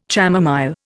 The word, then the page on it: chamomile: Indian